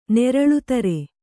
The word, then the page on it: ನೇರಳುತಾರೆ: nēraḷutāre